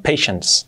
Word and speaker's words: patience: Pero paciencia, como tal, la paciencia, se escribe patience, pero la pronunciación es patience. Parece que es una T, pero no, patience. Es la N y la S ahí, patience, ¿vale? Patience, patience, acabado en S.